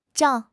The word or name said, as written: zhao4